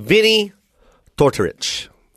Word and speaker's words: Vinnie Tortorich: And, man, I had an appointment at 2 o'clock with a very great fitness guru, I like to call him, named Vinnie Tortorich.